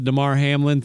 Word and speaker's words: Damar Hamlin: I'm not on the conspiracy train that because of the Damar Hamlin thing. I'm sorry. Did I say his name right? – You did.